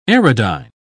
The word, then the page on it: aerodyne: British English